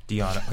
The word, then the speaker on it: Deonna: I said Donna, but I forgot the E.